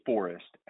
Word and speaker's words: Forrest: So my name is Forrest, F-O-R-R-E-S-T.